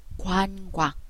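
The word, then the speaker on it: 관광: 관 광。韓国でですね、リズボーインがあるんです。それが、おとあが一緒になって、おわ、おわ、おわ、おわが連続発音してて、わになるんです。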